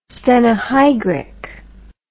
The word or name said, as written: stenohygric